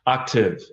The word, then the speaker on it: Octave: It's Octane, right? – Octave. It's funny. I have a way of making brands that are hard to pronounce. But Octave, kind of like the sound.